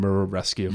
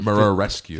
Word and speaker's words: Muraresku: Murrah Rescue, is that how you say it? – Brian Murrah Rescue. – Murrah Rescue.